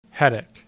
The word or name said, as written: Hettick